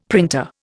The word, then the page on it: printer: Indian